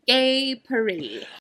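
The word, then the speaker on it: Gay Purr-ee: It is spelled purr, P-U-R-R, dash E-E. Gay Paree.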